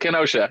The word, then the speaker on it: Kenosha: How do you say Kenosha? – Yeah, yeah that's it, Kenosha.